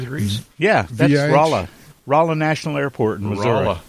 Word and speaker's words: Rolla: Is that spelled like Raleigh? How do you spell Rolla? – R-O-L-L-A. Rolla. – Rolla, okay. – Just like it sounds.